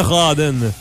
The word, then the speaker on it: Rawdon: Faut prononcer ça Rawdon. – En tout cas, nous, on le prononce comme ça.